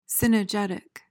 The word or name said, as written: cynegetic